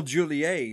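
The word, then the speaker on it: Joliet: Well, how about we head to Illinois to the old Juliet prison? I hope I said that right. – Juliet. Oh, Juliet. Yeah. Sorry. Juliet.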